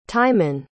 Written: Timin

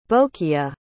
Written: bocleah

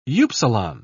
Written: upsilon

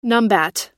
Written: numbat

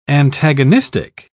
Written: antagonistic

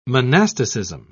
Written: monasticism

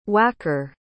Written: wacker

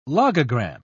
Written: logogram

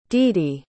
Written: dede

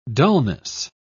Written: dullness

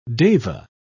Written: deva

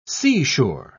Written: seashore

